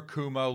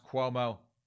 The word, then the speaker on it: Cuomo: "Human Rights Campaign Ousts Alphonso David Over Kumo Link." And yes, folks, I do know it's pronounced Cuomo. I just like to say Kumo to insult that clown car of a family.